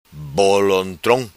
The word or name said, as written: bolontrom